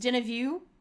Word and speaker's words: Deneuve: Susan Sarandon and God, Catherine Deneuve, I can't pronounce French things. Deneuve. – Yeah.